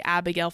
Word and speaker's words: Abigail: So my Instagram, my business Instagram is Keely Abigail Photography. It's K-E-E-L-E-Y. I don't know if I'm supposed to spell that. Yeah, Abigail, A-B-I-G-A-I-L Photography.